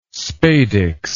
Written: spadix

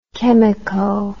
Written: chemico

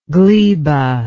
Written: gleba

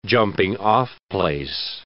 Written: jumping-off place

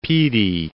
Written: Pee Dee

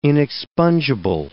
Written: inexpungible

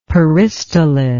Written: peristalith

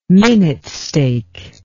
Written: minute steak

